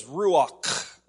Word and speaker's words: ruach: Now, the right way to say that is ruach. You know, like you have a hairball stuck down in your throat, or, you know, you got choked up on a little bit of toothpaste in the morning, you know.